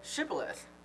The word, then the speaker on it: Shibboleth: And every Ephraimite had to say the word Shibboleth.